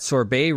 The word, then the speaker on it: sorbet: So the catch says the catch is that sorbet rhymes with day.